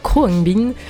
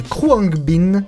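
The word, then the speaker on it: Khruangbin: donc, on fait un saut dans le temps et plus précisément dans les années 70 avec le trio texan, donc Kruangbin, pêle-mêle, alors des influences qui vont du funk thai, oui, ça existe, aux sonorités espagnoles et au Moyen-Orient, et plus précisément l'Iran.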